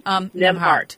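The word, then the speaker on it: Nembhard: Yeah, and I think thinking some of this resilience from our history, and so going back to the book, Jessica Gordon Nemar, I'm pronouncing her last name correctly. – Nemhart.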